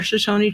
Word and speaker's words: Shoshone: So you guys are part of the Shoshones, correct? – Shoshone. – Shoshone. That's how you say it.